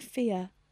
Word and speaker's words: Fieh: And I think that's how you say it. Fear. F-I-E-H. – F-I-E-H.